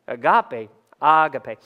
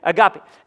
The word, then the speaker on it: agape: The Bible uses this word abake. Uh, I'm sorry. See, it messes me up to pronounce it correctly. It's so crazy. Agape, agape, okay? Agape.